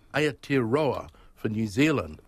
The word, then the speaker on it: Aotearoa: Aotearoa.